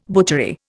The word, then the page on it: butchery: Indian